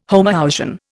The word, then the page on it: homoousian: Indian